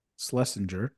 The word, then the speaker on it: Schlesinger: Schlesinger.